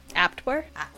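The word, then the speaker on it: Abwehr: It's A-B-W-E-H-R. – Aptwer? – Aptwer.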